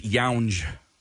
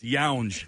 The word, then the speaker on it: Young: You did a nice job with all the names, but you pronounce Ramona, it's Younge. – Shut up. – That was the only one, Young. – I bought it. – Yeah. It's Younge, not Young.